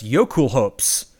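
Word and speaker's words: Jökulhaups: It's pronounced Yokul Hopes, but it's Yokul, like J-O-K-U-L, like from Norse, H-A-U-P-S, Yokul Hopes.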